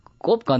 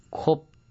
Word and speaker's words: Coop: 그 볼로냐 사람들은 꼽 간다 그럽니다. 꼽이요? 꼽이 협동조합 줄임말이 영어로 COOP, 콥, 코업인데 이탈리아 발음으로 꼽이죠.